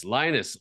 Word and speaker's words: Linus: Linus Allmark. – Linus. Oh, just like in Charlie Brown. – Yeah. – With the blanket.